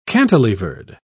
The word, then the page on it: cantilevered: British English